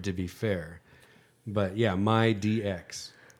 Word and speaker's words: MyDX: M-Y-D-X. – Oh, MyDX. Oh, the M D-X. And the D, okay. Yeah. That's like Mighty X. – No. – Just so it's my DX.